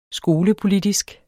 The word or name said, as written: skolepolitisk